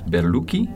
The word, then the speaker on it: Berlucchi: Is Berlucchi in Franciacorta. – Exactly. Oh, good pronunciation.